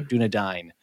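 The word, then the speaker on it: Dúnedain: Dunedain. I don't want to get in trouble with my pronunciations. – Dunedain is the plural. Dunedan right. is the singular.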